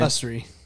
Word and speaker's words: Usrey: Usrey.